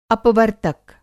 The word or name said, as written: अपवर्तक